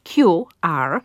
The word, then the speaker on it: QR: I teraz tak, ponieważ ten skrótowiec jest stosunkowo nowy, niedawno w polszczyźnie, można go potraktować jako jeszcze nieprzyswojony i wtedy należy go wymawiać po angielsku. I ta nazwa będzie brzmiała wtedy QR, bo tak są wymawiane odpowiednie literki po angielsku. Możemy go też spolszczyć, zapożyczenia przecież ulegają spolszczeniu i wtedy powinna ta nazwa brzmieć QR, bo w taki sposób polskie litery odpowiednio czytamy.